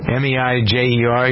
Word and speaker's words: Meijer: Is it Major Meyer? How do you pronounce that? I don't know that store chain. – Don't know it. – Meijer.